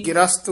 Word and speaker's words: ग्रस्त: रहाओ माया ग्रस्त प्रमत है प्राणी। – ग्रस्त उच्चारण करना गगे तो चक के माया ग्रस्त प्रमत है प्राणी।